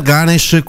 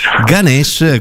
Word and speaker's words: Ganesh: We are Ganesh, qui con, in diretta dei Ganesh. Lo pronuncio bene? Ganesh? – Ganesh, Ganesh con l'accento, quindi sulla... Ganesh, quindi un gruppo che inizialmente nasce come DJ, o meglio il DJ sei tu.